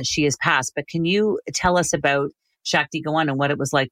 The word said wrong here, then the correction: Shakti Gawain: Yeah, it's actually Shakti Gawain is how you pronounce it, Shakti Gawain.